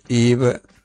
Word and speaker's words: Yves: che Yves è scritto Yves con la Y, sì. – ma non si pronunciano sì. mai le ultime due lettere.